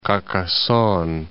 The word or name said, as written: Carcassonne